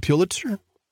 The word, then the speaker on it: Pulitzer: Let me begin with 20 leading journalists, including winners of several Emmys and Pulitzers, or how do you pronounce it, Pulitzer? – I think it's Pulitzer, I believe.